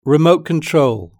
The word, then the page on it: remote control: British English